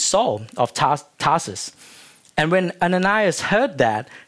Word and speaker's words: Ananias: Another scene going on is in Damascus, there was a disciple named Ananias. Did I pronounce that correctly? Yep.